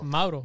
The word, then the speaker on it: Mauro: No, how do you, how do you say that guy's name? – What? – Moro. – Mauro. – Mauro. – Yeah, he's Italian.